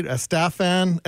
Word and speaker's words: Estefan: She also took me to see the Oak Ridge Boys, Miami Sound Machine with Gloria Estefan. Did I say that right? Estefan.